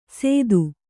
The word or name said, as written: ಸೇತು